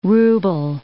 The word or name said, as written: ruble